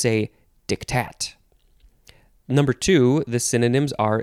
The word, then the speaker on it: diktat: Diktat. Actually, you emphasize the second syllable, so it's diktat.